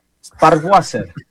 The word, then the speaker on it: Sparwasser: Sparwasser.